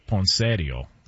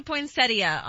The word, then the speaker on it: poinsettia: Well, the in-laws would call it poinsettio. – Oh, gosh. – Okay. What up? You know what I'm saying? Can we get the proper pronunciation on this? – It does say poinsettia online.